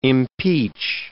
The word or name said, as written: impeach